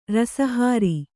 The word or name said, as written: ರಸಹಾರಿ